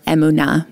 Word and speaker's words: emunah: Yes, the next word we're going to talk about, we mentioned already, it's the word emunah, or emunah, more properly pronounced in Hebrew.